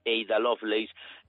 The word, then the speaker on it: Ada Lovelace: E isto é algo que hai que cambiar, e sobre todo hai no que cambiar se falamos do caso de Ada Lovelace, ou como pronuncian os ingleses, Ada Lovelace,